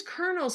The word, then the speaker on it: colonel: Why is colonel spelled with an L and pronounced with an R?